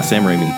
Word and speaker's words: Raimi: Sam Raimi, is that how you say that? – Yeah, Sam Raimi. – Ram-I? – It's pronounced Sam. – I'm never sure. Sounds weird. – What? – Sorry, keep going. – It's Raimi.